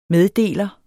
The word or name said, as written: meddeler